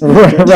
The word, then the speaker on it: Madrid: From December 1811 through February 1812, the area around New Madrid, which, by the way, is spelled Madrid, but pronounced Madrid. – Right. But of course we pronounce it Madrid.